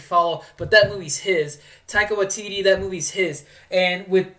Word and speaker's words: Taika Waititi: Taika Waititi. That's before I looked it up, too. That's two for two. Yeah. Taika Waititi.